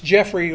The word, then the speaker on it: Geoffrey: And it's Jeffrey, like the Brits spell it, G-E-O-F-F-R-E-Y.